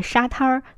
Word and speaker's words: 沙滩: 就像大石烂不能读成大栅栏一样，如果你把沙滩儿读成了沙滩的话，可能很多人都不知道你在说的是什么地方。